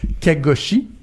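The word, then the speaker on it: Khashoggi: l'assassinat de Jamal Kagoshi. Je le dis bien? Oui, tout à fait.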